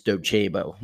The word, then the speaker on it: Docebo: I like Docebo's platform, which is D-O-C-E-B-O. The way you pronounce the company is Docebo. A lot of people say Docebo. It's Docebo.